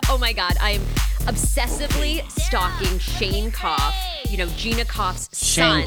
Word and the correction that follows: Keough: Shane Keo. – Oh, is that how you say it? Keo? – It's okay.